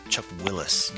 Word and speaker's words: Chuck Willis: and One More Break by Chuck Wills. You know what? Hold on just a second. I think I said that wrong. I can never remember. Yeah, Chuck Willis, not Chuck Wills.